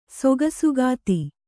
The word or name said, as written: ಸೊಗಸುಗಾತಿ